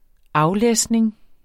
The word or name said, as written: aflæsning